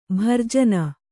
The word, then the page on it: ಭರ್ಜನ: Kannada